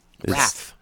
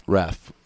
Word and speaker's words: Raph: So, And side note, this man here is a very, how do you say your name again? – Raph. – Raph? – Yeah, Raph. – R-A-F? – R- R-A-P-H.